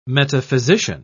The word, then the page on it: metaphysician: British English